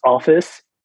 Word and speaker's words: office: I said offix. I didn't say office. But it was that friend who pointed it out to me that it was office. And I was really embarrassed at the time that I didn't say that correctly, that I remember to this day that it's supposed to be office with a softer S.